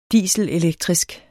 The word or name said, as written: dieselelektrisk